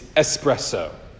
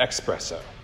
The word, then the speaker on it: espresso: It's espresso, not expresso. Get it right.